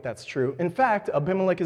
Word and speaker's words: Abimelech: Abimelech is just 2 Hebrew words put together. It's Av, father, and Melech, king. And when it's put together and pronounced this way, it's actually Abimelech.